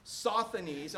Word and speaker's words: Sosthenes: They took this dude, sausage, and the ruler of the synagogue. All right, so how do you say it? Sosthenes, okay.